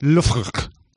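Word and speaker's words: Loughborough: Researchers from, hope I'm saying this right, Labra University, Western General Hospital. – It's spelled Labra, but it's pronounced Lufruk.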